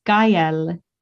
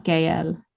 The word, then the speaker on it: Gael: Also, I just looked up how to say Stefan, the actor's name, and I've been saying it wrong. It's Gael, not Gael.